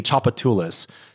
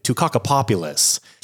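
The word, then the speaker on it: Tchoupitoulas: So if you're familiar with New Orleans, there's a very, very important street in the city, Chopatoulas. T-C-H-O-U-P is how you start off the spelling. I had a buddy of mine that lived here temporarily as a travel nurse, and he's from Boston, and he pronounced it Tucacapopulus.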